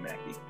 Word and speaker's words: McKie: And my last name looks like it's spelled McKee, but it's totally Mackey.